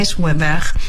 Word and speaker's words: Jules Verne: Jules Verne.